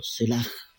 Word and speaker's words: Selah: Se escribe S-E-L-A con una H al final. Sela, con H termina. Podría pronunciarse, digamos, si fuera en hebreo, Selah, algo así, ¿ya?